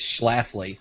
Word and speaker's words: Schlafly: The first question on their FAQ is how do you pronounce their name? It's Schlafly.